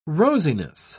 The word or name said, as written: rosiness